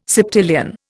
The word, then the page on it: septillion: Indian